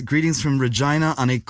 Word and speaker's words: Regina: Anyways, this person is writing with greetings from Regina, Saskatchewan in Canada. – Which I had mispronounced. – You said Regina, I think. I was so embarrassed because I knew the correct pronunciation, and I shut my yapper.